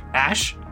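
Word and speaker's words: Æ: Ash? – Yeah, that's how you pronounce the symbol in the middle.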